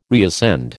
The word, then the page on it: reascend: American English